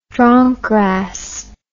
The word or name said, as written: dronkgrass